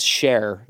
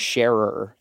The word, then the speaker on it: Scherer: And so, literally, she's like, no, our last name is Share, not Sharer. And I was like, that's crazy. And then some people pronounce it Sheer because it's just a German name.